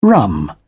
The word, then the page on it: rum: British English